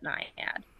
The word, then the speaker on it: Naiad: It can be pronounced Naiad or Naiad. People often ask me how to pronounce it, but I pronounce it Naiad.